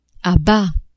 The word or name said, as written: a bas